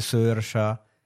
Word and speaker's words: Saoirse: Magari sai, visto che c'hanno le robe strane che si chiama, cioè Saoirse, che si legge Sersha, c'hanno queste cose particolari, no? – Barry.